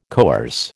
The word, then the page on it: coerce: American English